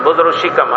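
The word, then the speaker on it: بزرگ شکم: فكنا نقول بزرق شكم بزرق شكم هكذا بزرق بمعنى كبير شكم بمعنى البطن وهذا هو النطق الصحيح باللغة الفارسية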